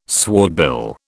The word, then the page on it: swordbill: British English